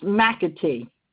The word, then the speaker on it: McAtee: Lynn McAtee. – I'm sorry, could you repeat that for me? – Lynn, LYNN, Mm-hmm. – M, capital ATEE. It's pronounced McAtee.